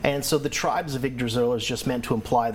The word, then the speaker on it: Yggdrasil: So the Tribes of, how do I pronounce it? – The tribes of Yggdrasil. – Yggdrasil, and what does Yggdrasil mean? – Yggdrasil's the world tree.